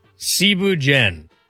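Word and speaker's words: Thieboudienne: First off, Cebu Jen. It's spelled like Thibu Dien.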